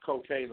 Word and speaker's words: Kokane: You can reach me on my Twitter at Cocaine Official.